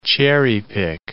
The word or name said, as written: cherry-pick